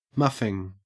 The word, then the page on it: muffing: Australian English